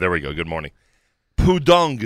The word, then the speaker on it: Pudong: Is Pudong the right pronunciation? – Yes.